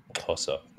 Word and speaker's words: Xhosa: Xhosa. It's a thick X. – I can't even do it. Yeah, Xhosa.